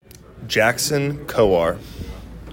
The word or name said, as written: Jackson Kowar